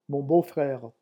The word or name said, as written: mon beau frere